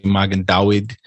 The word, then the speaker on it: Magen David: You know, we say Magen David. We pronounce the V's as W's, right? – And yeah.